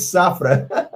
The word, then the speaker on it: Safra: Desculpa, falei Safira, é cliente Safra. Não é Safira, Safra. Falei errado.